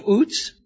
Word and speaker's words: Uz: We read, there was a man in the land of Uz, which is the way that this, from my research, the proper way to pronounce this, whose name was Job.